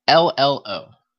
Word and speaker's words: Nardolillo: Nardolilo? That's how it looks to me. Nardolilo. – I don't see how it's spelled. – Yeah, it's Nardolillo. Nardolillo.